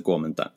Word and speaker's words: Kuomintang: The K should be pronounced as a G and the T as a D. So the KMT is the Kuomintang.